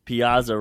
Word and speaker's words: Piazza: And then for Team Italy, Mike Piazza, because I'm Italian, so I'm gonna say it correctly. Piazza rocking the that stash.